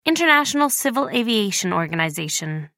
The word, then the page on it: international civil aviation organization: American English